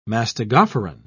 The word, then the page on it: mastigophoran: British English